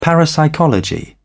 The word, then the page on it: parapsychology: British English